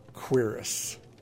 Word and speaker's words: cuirass: So the word for the covering of one's torso, C-U-I-R-A-S-S, in this case, the Quirrus of Savior's Hide, is pronounced Quirrus.